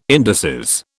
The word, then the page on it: indices: British English